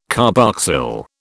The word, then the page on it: carboxyl: British English